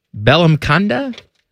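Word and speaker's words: Bellamkonda: How do we say this guy's name? Belamkonda? I feel like I'm doing it wrong. Teddy? – I think that's right. – You're a linguist. – I believe that's how you say it.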